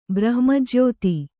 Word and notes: ಬ್ರಹ್ಮಜ್ಯೋತಿ: Kannada

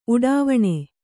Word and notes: ಉಡಿವಣಿ: kannada

